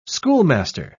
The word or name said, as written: schoolmaster